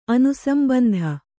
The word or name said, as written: ಅನುಸಂಬಂಧ